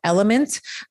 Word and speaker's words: LMNT: So if you are salting your food, I use a supplement called Element. It's spelled L-M-N-T.